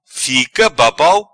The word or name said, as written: Fica babau